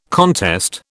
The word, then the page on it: contest: British English